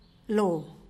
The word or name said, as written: loo